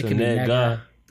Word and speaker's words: Negga: I think it's pronounced Negga. I don't know. – I said Negga. – I actually know how you pronounce that. – It's N-E-G-G-A. It's so Negga.